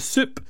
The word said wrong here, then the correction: Shoop Shoop: not the Soup, the Shoop Shoop Song is on the way after a news update with Andrew Baxter next.